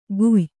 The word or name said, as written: ಗುಯ್ಯ